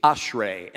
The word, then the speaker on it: Ashrei: This word, by the way, is pronounced, it's easy, Ashrei. Everybody say Ashrei.